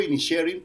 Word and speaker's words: Inisherin: Yo digo Inisherin porque supuestamente es lo más cercano al gaélico, pero Rafa Sarmiento dice Inisherin, que también puede ser más cercano al gaélico.